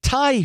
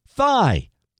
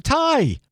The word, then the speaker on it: Thai: Thai food. Here's the tweet Yikes! According to a friend, DeSantis would tell dates he liked Thai food but pronounced it thigh.